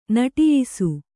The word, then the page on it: ನಿಟ್ಟಯಿಸು: niṭṭayisu